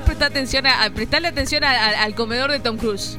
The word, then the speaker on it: Tom Cruise: Tom Cruise.